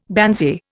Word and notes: banzai: Indian

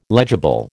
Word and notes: legible: American English

